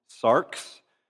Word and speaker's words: sarx: It's pronounced sarx. The Greek word sarx, and it is a word that means flesh.